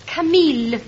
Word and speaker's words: Camille: Oi, camel, I say, camel. – Percy, I keep telling you, it is pronounced Camille.